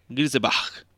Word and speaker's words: Grisebach: Nossa, é difícil falar esse nome. É Grisebach. Grisebach. Muito bem.